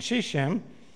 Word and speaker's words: Shechem: And Shechem,